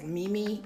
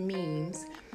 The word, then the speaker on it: memes: M-E-M-E. Now, I was Mimi. So they're called memes. It's pronounced memes.